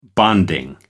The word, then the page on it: bonding: American English